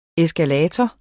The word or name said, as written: eskalator